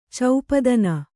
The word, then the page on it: ಚೌಪದನ: Kannada